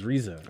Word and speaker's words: RZA: Oh, somebody said you don't pronounce it like the Wu-Tang RZA. Oh, sorry. So it's RZA, right? – It's RZA, yeah.